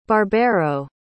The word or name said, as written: barbero